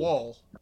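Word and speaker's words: Woll: Deborah Ann Knoll was... Did I say her name? Vol. – Vol. – Vol, yes.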